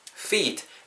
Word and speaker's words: feet: feet, f 사운드죠. 일단은 입술 다음 안 되고요. 윗니 아래 입술에 훅 긁으면서 발음해 주셔야 되고 feet you have to make it long 길게 발음하셔야 됩니다. feet not fit feet fit 하게 되면 그 fitness라 할때 그 fit 그게 되죠. fit이 되니까 feet 길게 발음해 주세요.